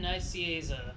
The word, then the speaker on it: Nicieza: Fabian Nicieza. – Oh, Nicieza.